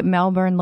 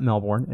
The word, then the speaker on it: Melbourne: Actually, Melbourne looks like Seattle, Washington, if anyone knows. – I teach you. Okay. – Oh, tell me how to say it. – Not Melbourne. It's Melbourne.